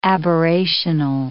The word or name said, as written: aberrational